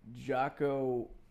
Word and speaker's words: Jocko: Do you know who like Jonko Willink is? Jonko Willink? Actually, his name is on the book right behind you, this black book. How is it pronounced? Jonko Willink? The black one? – Jocko Willink? – Yeah.